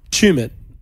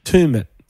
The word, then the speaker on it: Tumut: And Chumut is just a saying, and then some people pronounce it Tumut.